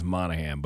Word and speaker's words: Monaghan: How did you say it? – Mahaganon. – Monaghan. – Monaghan. – Monaghan. I think it's Monaghan, but it's Monaghan for all intents and purposes.